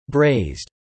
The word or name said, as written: Braised